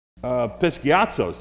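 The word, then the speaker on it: Episkiazo (ἐπισκιάζω): Episkiazo, see, I did say it wrong.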